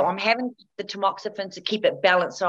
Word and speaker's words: Tamoxifen: And then the tax is it taxam taxamofen? Is that how you say it? – Tamoxifen.